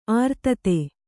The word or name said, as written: ಅರ್ಥಿತ